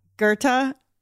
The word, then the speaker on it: Goethe: You know, Goethe? – Yes. – And I cackle because the spelling, you know, Goethes or whatever. It looks like Goethes. – Oh, right, sure. – Okay, but it's pronounced Goethe,